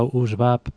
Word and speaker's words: Oozebap: Sí, en realidad se pronuncia USBAP porque es un juego de palabras entre US, que en inglés quiere decir rezumar, un poco un líquido que sale, y BAP, que en árabe es el umbral, ¿no?